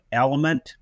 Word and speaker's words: Eliment: I believe it's called Element, with an emphasis on the L-I, spelled E-L-I-M-E-N-T, and emphasis on for lithium.